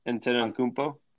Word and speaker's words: Antetokounmpo: Antetokounmpo? – Yeah, into a rap verse.